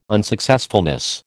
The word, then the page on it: unsuccessfulness: American English